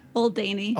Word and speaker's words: Oldanie: Oldanie.